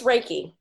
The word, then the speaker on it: Reiki: It's Reiki.